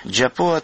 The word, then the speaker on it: Джопова: Джопова, так она произносится и пишется по-абхазски.